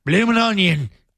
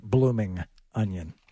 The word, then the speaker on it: Bloomin' Onion: Please delete the G next time you pronounce that. – What? – Bloomin'. – Bloomin' onion. Thank you. Would you like more sauce with your onion? – Don't mispronounce it and embarrass me and the entire United States by pronouncing it blooming onion, please.